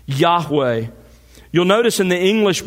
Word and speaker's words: Yahweh: a translation of God's personal name, pronounced Yahweh.